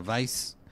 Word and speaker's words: Weisz: Y dice Mariana Castro Mercado, el apellido de Rachel Weisz se pronuncia Weisz.